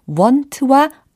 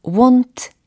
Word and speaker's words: won't: want와 won't 의 차이가 느껴지셔야 해요. 혹은 won't라고 발음을 하는 사람도 있습니다. 어, won't라고 해도 되고 won't라고 발음을 해도 되는데 want는 아니라는 거예요. 이 차이를 아시겠나요?